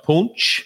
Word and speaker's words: Punch: The Punch. La Punch. It's appropriately pronounced Punch.